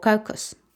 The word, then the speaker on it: caucus: Uno se llama las primarias y otro se llama Caucasus o Caucus, que se escribe C-A-U-C-U-S, que lo vamos a traducir o lo voy a manejar ahorita como juntas electorales.